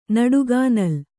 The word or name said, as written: ನಡುಗಾನಲ್